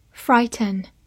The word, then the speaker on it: frighten: Frighten。 F R I G H T E N，frighten，F 发 f，R I 发 r，G H 不发音，T E N ten，frighten，frighten，它是一个动词，表示使惊吓或者吓唬。